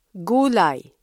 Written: gulay

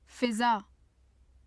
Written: fiza

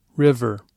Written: river

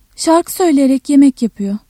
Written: söyle-

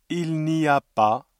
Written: il n'y a pas

